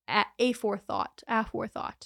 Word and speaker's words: aforethought: And then they proceeded to ask them about malice afterthought. Aforethought is how you pronounce that.